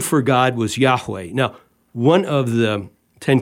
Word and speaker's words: Yahweh: Nobody knows how to pronounce it, but the best we can do, we think it's pronounced Yahweh.